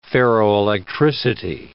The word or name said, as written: ferroelectricity